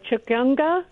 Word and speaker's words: Chikungunya: Chikungunya.